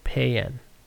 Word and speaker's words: paean: Payan? P-A-E-A-N. Man, I'm looking at a lot of words today. A song of praise or triumph. A thing that expresses enthusiastic praise. It's pronounced payen. Payen.